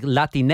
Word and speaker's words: Latine: And really quickly, Latine, as sounds exactly how it's spelled, L-A-T-I-N-E, and it's coming from South America.